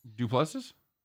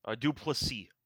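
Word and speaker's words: Du Plessis: Dupluses? – Duplessis. – Okay. All right. – Close enough. – All right. – Close enough. – Duplessis, Hall, Miller, Gary O'Malley.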